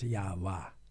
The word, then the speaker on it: Yahweh: Geschreven Yama, waar waarschijnlijk uitgesproken als Yahwa. He, dus daar zien we ook een bevestiging van die uitspraak. Yahweh en zo.